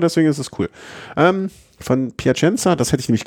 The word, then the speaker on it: Piacenza: Von Plaisson, nee, wie spricht man das aus? – Piacenza. – Hä? Aber warum? Aber dann ist es falsch geschrieben. – Das sind natürlich die französischen Namen, die du auf der Tour de France-Seite hast.